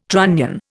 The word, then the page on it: trunnion: Indian